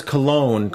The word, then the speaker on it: Colón: En, español es Colón, como perfume. Sí, como perfume.